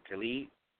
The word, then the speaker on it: Kali: The first one being, is her name Kali, K-A-L-I? That's how you pronounce it, Kali? – Kali, uh-huh.